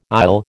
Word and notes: aisle: American English

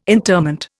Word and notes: interment: Indian

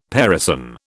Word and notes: parison: British English

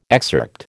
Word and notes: excerpt: American English

